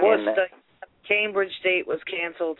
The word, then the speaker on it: Worcester: On the thing it said, last time I looked, it said Worcester, but it was spelled like W-O-O-S-T-A. Worcester.